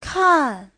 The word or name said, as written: kan4